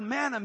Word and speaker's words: manna: The word, that word manna means, and I want you, this is the way you pronounce it.